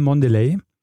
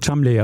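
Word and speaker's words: Cholmondeley: Ich habe äh, einen der Protagonisten, den habe ich genannt, äh, Joel Mondeley. Und ich muss sagen, man schreibt ihn auch so, aber man spricht ihn nicht so. – ähm, spricht man wirklich? – Man spricht ihn Chumley aus.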